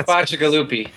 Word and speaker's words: Bacigalupi: It's Bacigalupi.